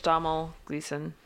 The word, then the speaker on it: Domhnall Gleeson: No, I'm pretty sure it's Domhnall Gleeson. He's said it before. It sounds like you're saying Donald, but you're not.